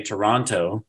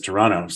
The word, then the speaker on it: Toronto: Notice, everyone, I want you to notice Micheal does not say Toronto. He says Toronto. So I want you guys to just make note of that. If you want to sound like you know what you're talking about, don't pronounce the T, okay? – Hey, I could be wrong.